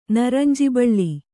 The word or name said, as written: ನರಂಜಿಬಳ್ಳಿ